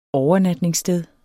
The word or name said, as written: OVERNATNINGSSTED